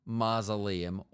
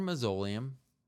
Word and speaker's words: Mausoleum: I will almost guarantee that if you had not seen the movie Mausoleum, or Mausoleum, if you want to pronounce it that way, it's wrong, but if you want to, I won't say anything.